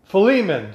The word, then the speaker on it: Philemon: to Philemon, no, P-H-E-L-M-O-N, how do you say that? I can't pronounce it. I'm just going blank. And my coworker here is not helping me. She must have disappeared on me. But nonetheless, if you look in Philemon, there we go.